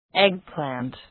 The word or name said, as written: eggplant